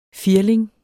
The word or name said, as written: FIRLINGER